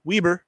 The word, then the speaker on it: Weber: But Taron Johnson out of Weber State, not Weber, Weber, they will correct you, even though it's spelled like the Weber Grills.